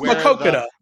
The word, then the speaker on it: Maquoketa: He came back this weekend, come back and wins a race at Maquoketa. I'm not even sure how to say that. Do you know that? Do you know how to say it's Maquoketa? – Where the Nippy 50 Maquoketa.